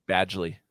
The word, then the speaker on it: Badgley: Penn Badgley? Is that how you say it? – Badgley.